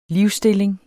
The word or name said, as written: livsstilling